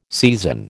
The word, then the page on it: seisin: American English